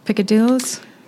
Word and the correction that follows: peccadillos: How do you say it's, is it peccadillo? Yeah, peccadillos. It's Piccadilly Circus. – Piccadilly Circus. Peccadillos. – Yeah.